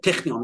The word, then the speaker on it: Technion: called the Technion, or the Technion. That's how you pronounce it.